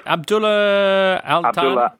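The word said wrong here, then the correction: Abdullah Al Thani: Sheikh Abdullah Altani.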